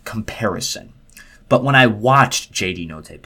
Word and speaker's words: Notae: J.D. Notae, and I believe I pronounced that right. N-O-T-A-E.